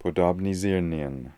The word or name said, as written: Podobnisirnian